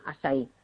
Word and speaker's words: açaí: Si lo queréis buscar escrito, que se escribe A con la cedilla, como si fuera esta portuguesa, y se llama eso acai orgánico, y se pronuncia açaí.